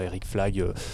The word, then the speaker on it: Eric Flag: Eric Flag, ça s'écrit ça comme ça se prononce, et c'est aussi pour ça que j'ai choisi ce, nom.